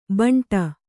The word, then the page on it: ಬಂಟ: kannada